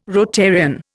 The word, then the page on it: Rotarian: Indian